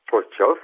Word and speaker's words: Prostějov: Elle est distillée, alors je n'ai pas la prononciation exacte, à Prostějov, en Moravie. – Prostějov, voilà, exactement, en Moravie.